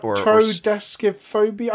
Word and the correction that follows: Triskaidekaphobia: I could never pronounce it then, but yeah, I know. – Yeah, Triskaidekaphobia, so that's it.